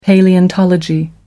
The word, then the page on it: palaeontology: American English